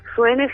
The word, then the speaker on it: Suhene: Suene se escribe S-U-H-E-N-E.